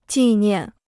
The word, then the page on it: 纪念: jì niàn